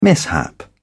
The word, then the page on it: mishap: British English